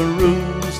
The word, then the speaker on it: Ouse: It is made by Harvey's Brewery in the old Sussex town of Lewes, which is by the River Ouse that rhymes with booze, and we think this beer beats the rest.